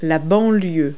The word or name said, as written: suburb